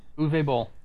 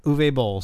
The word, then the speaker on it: Uwe Boll: So if anybody doesn't know, I think it's pronounced Uwe. – Uwe. – Uwe? – Uwe Boll. – Uwe Boll.